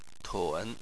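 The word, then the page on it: tun: tone 3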